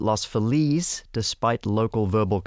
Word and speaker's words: Los Feliz: Mark Andrada said, I went to Los Feliz Elementary in 1978 as a fifth grader, and both the kids and staff pronounce it exactly like it is in Spanish, Los Feliz.